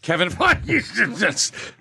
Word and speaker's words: Feige: I wanted to jump in and say, Todd, that is the best pronunciation of his name without you actually resorting to coughing. Kevin Feige. Yes.